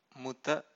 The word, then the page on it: muta: Pali